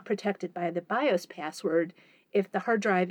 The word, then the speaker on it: BIOS: So, BIOS is that acronym you see all the time, B-I-O-S.